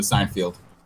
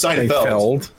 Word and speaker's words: Seinfeld: You've seen Renaissance Man, but not one episode of Seinfeld. – First of all, it's Seinfeld. Seinfeld. – Whatever. – So you can't even judge me. You can't even can't pronounce the damn show.